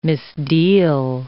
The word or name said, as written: misdeal